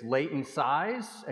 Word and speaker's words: Leighton Size: My name is Leighton Size, and you can remember it because it rhymes with Satan Lies.